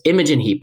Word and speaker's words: Imogen Heap: Although it's Imogen Heap, by the way, I know someone's going to be mad at me for pronouncing it wrong before.